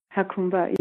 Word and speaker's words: Jacumba: You made it in a very small place called, and I apologize if I'm pronouncing this wrong, Jacumba, California. – Jacumba, yes.